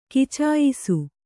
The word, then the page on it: ಕಚಾಯಿಸು: kacāyisu